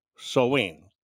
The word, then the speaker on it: Samhain: They celebrated this Samhain, and it's kind of interesting, because even though you say it Samhain, the way you spell it is Samhain, but it's pronounced Samhain.